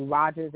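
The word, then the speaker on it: Rodgers: It's on Amazon currently, and it's called The A's Habit, Abandonment, Abortion, Addiction by Sandy Rogers, and that last name is spelled with a D.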